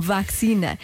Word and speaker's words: vacina: O mesmo não sucedeu como vacina e será o destino de ação. A vacina com vogal aberta não é regionalismo, é a forma correta de a pronunciar.